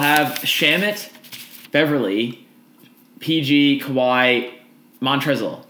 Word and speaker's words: Montrezl: Say you'll start Montrezl with an L. No silent L. There's an L there.